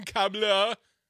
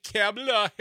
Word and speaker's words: Kamala: casting her as lying Kamala. Except he mispronounces it, so he says, like Kamala Harris,